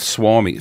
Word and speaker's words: Suomi: It's actually called Suomi. – Called what? – Suomi. Finland is spelled S-U-O-M-I.